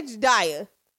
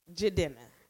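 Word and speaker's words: Jidenna: Okay, I went to see Jay Dinner. – Is that how you say his name? Because I say Jedaya.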